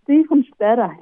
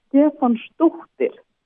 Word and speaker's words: Stefánsdóttir: ja, det er Stefan Stadder, eller Stefan Stochtel. – Okay. det... man skal udtale det på godt islandsk.